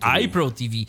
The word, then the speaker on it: iPro TV: Bo dziś zaprezentujesz nam aplikację, która nazywa się iPro TV, a właściwie iPro TV, tak się ją powinno właściwie wymawiać.